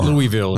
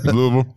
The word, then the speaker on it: Louisville: It's not Louisville. It's Louisville. You don't have to move your mouth.